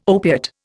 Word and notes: opiate: Indian

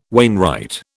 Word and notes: wainwright: British English

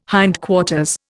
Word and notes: hindquarters: Indian

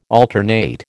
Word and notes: alternate: American English

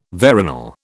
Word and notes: veronal: British English

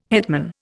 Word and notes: hetman: Indian

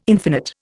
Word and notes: infinite: Indian

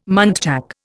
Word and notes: muntjac: Indian

